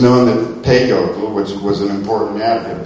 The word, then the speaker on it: peyote: And this, again, was called peyote, and some people say peyote, but it really is accented peyote. Sagan spelled it P-E-I-O-T-L, which would be pronounced peyote.